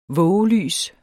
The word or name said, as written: vågelys